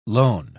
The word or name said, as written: lone